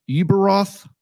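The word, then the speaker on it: Ueberroth: It's got a U and an E and a B and an E and an R. Uberoth. – Oh, Peter Uberoth.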